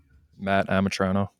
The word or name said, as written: Amitrano